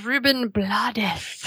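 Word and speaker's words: Rubén Blades: Oh, and I also learned that the correct pronunciation of The actor's name is Ruben Blades.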